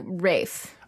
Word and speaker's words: Ralph: Because it's pronounced like Rafe.